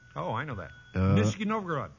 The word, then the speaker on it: Nizhny Novgorod: Nizhny Novgorod.